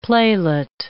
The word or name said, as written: playlet